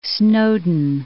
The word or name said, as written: Snowdon